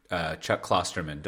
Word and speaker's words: Chuck Klosterman: One, last episode, you might remember that I talked about discovering Chuck Klosterman.